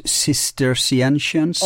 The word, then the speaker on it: Cistercians: No, I'm pronouncing it badly, but Cistercians.